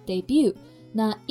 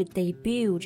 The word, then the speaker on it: debut: 对，它和一般的动词 make 连用，就可以说 make one's debut。那 Eve Jobs 这次拍摄的照片呢，就是她的模特的首秀。 – 对我们特别要注意这个 debut 这个单词的发音啊，千万不要看着它的拼写 d e b u t 读成了 debut，这样就不对了。结尾的 t 是不发音的，而且它的重音是在第二个音节上面 debut。